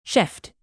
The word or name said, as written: shift